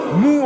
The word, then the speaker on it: Moux: Mou en Morvan, ça s'écrit M-O-U-X, Mou.